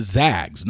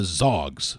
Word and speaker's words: Zags: even though it's the Zags, not the Zogs. So that's every time I say Gonzaga, I have to think the Zags, because that's usually what's on their jersey. It says Zags. So you don't pronounce that Zogs.